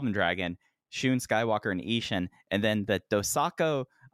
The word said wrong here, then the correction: Dosanko: Um, let me pronounce that again. Dosanko Derby six man tag team match.